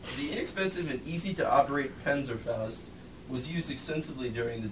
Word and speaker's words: Panzerfaust: Panzerfaust, yeah.